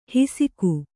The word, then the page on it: ಹಿಸಿಕು: hisiku